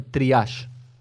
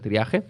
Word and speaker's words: triage: con el tema del triage. ¿Escuchaste nombrar el triage? Creo que se pronuncia así, o triage, no creo que se pronuncie triaje. Se escribe T-R-I-A-G-E.